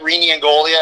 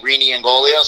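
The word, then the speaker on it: Rene Ingoglia: Yeah, it's just at my name, at Rene Ingolia. It's R-E-N-E-I-N-G-O-G-L-I-A. And if you can't spell it, once you start trying to type it in, I can guarantee you I'm the only Rene Ingolia.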